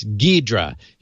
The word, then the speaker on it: Ghidra: It is pronounced Ghidra. – Yeah, that's what I thought. The H is silent. – Yes, Ghidra.